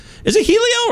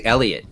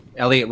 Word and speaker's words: Heliot: Is it Helio or Heliot? I haven't heard the pronunciation. I honestly don't know. – I think they say Elliot, actually. – Oh, shit. Really? – Like the H okay. is silent. Yeah. Elliot Ramos. – Oh. Elliot. Like E.T. Elliot.